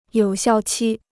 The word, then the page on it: 有效期: yǒu xiào qī